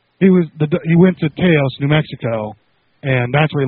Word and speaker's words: Taos: Taos.